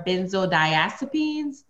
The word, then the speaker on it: benzodiazepines: Short for benzodiazepines. Did I say that right, Eb? – You said it right, bud.